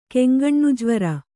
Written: ಕೆಂಗಣ್ಣುಜ್ವರ

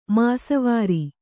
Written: ಮಾಸ್ವಾರ

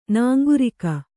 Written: ನಾಂಗುರಿಕ

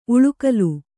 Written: ಉಳಿಗಾಲ

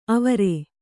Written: ಆವರು

